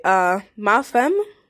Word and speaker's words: femme: Anyway, ma femme is weird because it looks like femme, but from my phonetics and diction class, I learned that you don't even say femme, so I've been saying it wrong. You say with the ah.